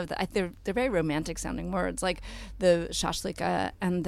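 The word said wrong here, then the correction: Shashliki (шашлыки): That, did I pronounce that right? Shashliki. – Yeah, like the, the plural the, of shashlik.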